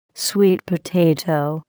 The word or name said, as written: sweet potato